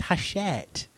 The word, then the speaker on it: Hachette: Now, Hachette, that sounds remarkably like Battle Axe. Hachette, French company. – There, you know, there's an entire British sitcom in there. It's no, it's pronounced Hachette.